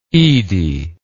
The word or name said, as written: edie